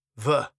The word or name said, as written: the